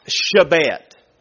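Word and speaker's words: shebet (שבט): The word rod in the Hebrew is the word pronounced shebet. S-H-E-B-E-T.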